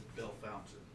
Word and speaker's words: Bellefontaine: It's Bellefontaine. – Yeah. I like to pronounce it Bellefontaine, the way it's written out.